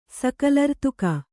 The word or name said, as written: ಸಕಲರ್ತುಕ